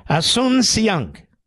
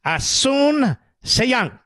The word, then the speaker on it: Asunción: Asunción. Everybody after me. Asunción. Asunción.